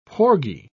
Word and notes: porgy: British English

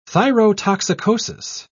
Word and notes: thyrotoxicosis: British English

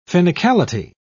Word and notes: finicality: British English

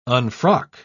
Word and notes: unfrock: British English

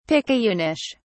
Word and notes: picayunish: British English

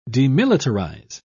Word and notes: demilitarise: British English

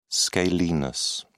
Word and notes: scalenus: British English

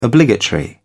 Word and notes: obligatory: British English

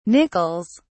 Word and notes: nickels: British English

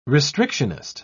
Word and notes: restrictionist: British English

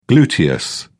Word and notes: gluteus: British English

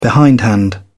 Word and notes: behindhand: British English

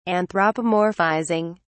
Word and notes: anthropomorphizing: British English

